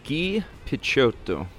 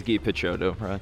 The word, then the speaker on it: Picciotto: Guy Picciotto. He doesn't say it like that. He says Guy Picciotto.